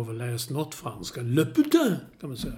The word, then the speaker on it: putain: Uttala det på franska för det betyder hora. På franska, ja. Putain.